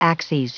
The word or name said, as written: axes